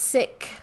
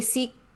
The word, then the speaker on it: Sikh: So it is pronounced sick with an aspirated H. But if you say Sikh, most likely we will not correct you, because we are just relieved that you know who we are.